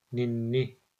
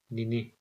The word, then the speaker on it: ninni: Ninni. With a single N, the last word would be pronounced as nini. With double L, ninni.